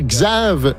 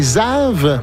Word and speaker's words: Xav: J'allais dire Xav, mais c'est Zav. – Pour Xavier, comme on le prononce en anglais.